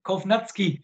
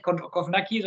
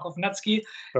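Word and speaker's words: Kownacki: Also wer mir richtig gut gefällt, ist Kovnatski, wird da, glaube ich, ausgesprochen. Ne? Nicht Kovnacki, sondern Kovnatski.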